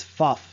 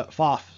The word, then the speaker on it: Pfaff: They pronounce it, if you go to the UW Badgers website, it is Pfaff. So, Pfaff.